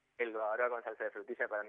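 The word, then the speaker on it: bavarois: Babaroa se pronuncia. Pero Babaroise.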